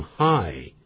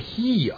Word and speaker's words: hi: 打招呼的时候可是要用 hi, h-i, 这个可不要念成 he 啊， he Tom, 那人家也会觉得好怪啊。